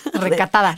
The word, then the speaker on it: recatada: No, yo soy la esposa tengo que ser una retacada, o cómo se dice esa palabra? Recatada. – Recatada. retacada, no. Retacada, no. No, es recatada.